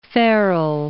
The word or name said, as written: Farrell